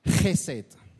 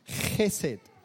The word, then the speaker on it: Chesed: Now, the Hebrew word used for steadfast love, that's actually one word, is a word that you're all going to try and pronounce with me. Chesed. So if you don't spit on the person next to you, you're saying it wrong. I'm joking. Please don't. Yeah, spit into your mouth.